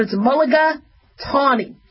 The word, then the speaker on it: mulligatawny: Mulligatawny, you spell it M-U-L-L-I-G-A and then tawny. So T-A-W-N-Y. Like getting a mulligan, but it's mulligatawny.